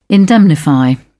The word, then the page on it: indemnify: British English